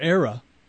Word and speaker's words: ARA: Project ARA, or ARA, spelled like Ara Parseghian, A-R-A.